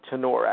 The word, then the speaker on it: Tenore: For the record, it's pronounced tenure, actually. – Tenure, thank you very much. – Yes, the E is silent, so everybody gets that wrong. – Oh, yeah, the second E is silent, yeah.